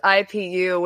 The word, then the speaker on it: IPU: Don't pronounce the IPU without the dots in between. Like, don't say it as a word.